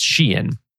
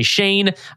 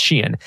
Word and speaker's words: Shein: That's why it's pronounced Shein. A lot of people say Shane. I'm pretty sure it's Shein.